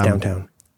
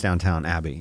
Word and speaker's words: Downton Abbey: And I think it's downtown. – It is pronounced Downton Abbey, yes.